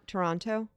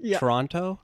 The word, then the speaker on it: Toronto: Toronto. – Like, you don't say the second T.